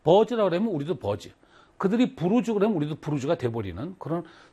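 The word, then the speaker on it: Burj: 아, 이거를 버즈로 읽으면 안 되는구나. 부르즈로 읽어야 되는구나. 라고 해서, 칼리파는 또 부르즈 칼리파라고 읽었어요.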